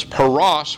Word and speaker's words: paras: The first occurrence is at the beginning of the verse, and it's pronounced peres, half mina. Its second occurrence is a passive verb written in the PL or intensive stem where it's pronounced pirasat and translated divided. And then the third occurrence is the last word in the sentence, paras, which means Persians.